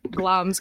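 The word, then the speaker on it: Glamis: Aye, so as we said, it's pronounced Glam's Castle,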